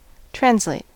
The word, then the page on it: translate: American English